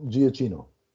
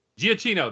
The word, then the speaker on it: Giacchino: Michael Giacchino. – Giacchino.